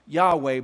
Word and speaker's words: Yahweh: We know that's not how to pronounce it because of the J sound. And most scholars agree that this is two syllables and probably was pronounced Yahweh, but we do not know.